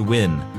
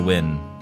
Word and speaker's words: Nguyen: Whoops, I've always, what? Pronounced Win. I've been saying that wrong my whole life. Mariana Win. – Nguyen. – Yeah. Win. See, they put the pronunciation on there and I mess it up anyway.